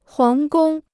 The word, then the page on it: 皇宫: huáng gōng